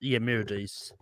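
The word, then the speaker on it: Merediz: I think it's Meredes. – Meredes. Olga Meredes. Yes, thank you.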